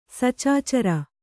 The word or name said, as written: ಸಚಾಚರ